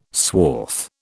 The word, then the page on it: swath: British English